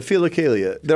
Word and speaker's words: philokalia: He has something, and I'm going to terribly mispronounce it, correct me. He has something called a philokalia. – Yeah, the philokalia.